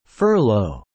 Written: furlough

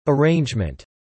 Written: arrangement

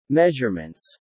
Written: measurements

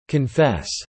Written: confess

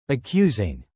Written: accusing

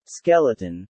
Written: Skeleton

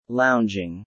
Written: lounging

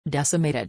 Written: decimated

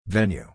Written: venue

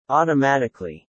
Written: automatically

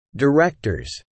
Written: directors

